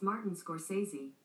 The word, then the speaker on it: Scorsese: How do you pronounce Martin Scorsese? – That's pronounced Martin Scorsese. – Okay, Google has confirmed it.